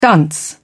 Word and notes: dunce: British English

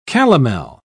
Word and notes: calomel: British English

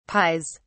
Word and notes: pies: British English

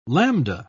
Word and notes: lambda: British English

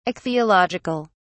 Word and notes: ichthyological: British English

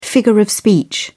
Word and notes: figure of speech: British English

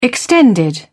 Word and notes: extended: British English

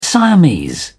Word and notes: siamese: British English

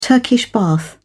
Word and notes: Turkish bath: British English

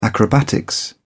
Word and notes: acrobatics: British English

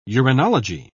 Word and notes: uranology: British English

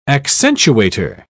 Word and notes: accentuator: British English

